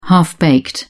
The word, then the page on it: half-baked: British English